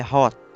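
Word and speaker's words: Hoth: É até esquisito porque o, o nome do planeta é Hot. hot é, é hot, a pronúncia correta brasileira.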